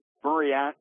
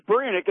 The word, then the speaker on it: Burianek: Right now, guys, the head coach, Jason Burianek. There's an extra syllable in there. Okay, that's the one that tricked me. Well, Coach Burianek is also defensive coordinator Burianek,